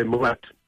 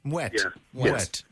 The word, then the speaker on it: Moët: Normally they say Mouet. – Mouet. Okay. – So they pronounce the T.